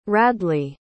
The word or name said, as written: radley